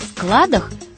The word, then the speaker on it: склад: Только об ударении во множественном числе забывать не стоит. Оно всегда падает на первый слог, то есть неподвижное. А как же иначе? На складе, на складах все должно быть в полном, постоянном порядке.